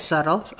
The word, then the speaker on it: subtle: How do you pronounce that word, by the way? – Yes, subtle.